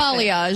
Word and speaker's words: balayage: Balayage, is that how you say it? How do you balayage? – Balayage, that's it.